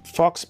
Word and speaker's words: Phawx: I am The Fox on YouTube, Fox, PHAWX.